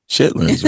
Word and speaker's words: chitterlings: It's spelled C-H-I-T-T-E-R-L-I-N-G-S. But nobody I know pronounces it that way unless you're edumacated. – Chitlins, right? So that's chitlins, right? – Yeah, but it's spelled chitterlings.